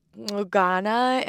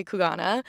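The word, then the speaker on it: Xugana: Well, actually in Bushman language, it's Kugana. And there's like a little click on the X, but I'm still working on my pronunciation of that. But they encourage tourists to just say Kugana.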